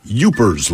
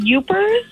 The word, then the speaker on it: Yoopers: You said youpers? – Youpers. – Like U-P-E-R-S? – That's uppers. That's a drug. – Yeah. What the hell is wrong with you? – But they actually spell it Y-O-O-P-E-R-S, but you're tracking.